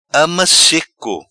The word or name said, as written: Ama seco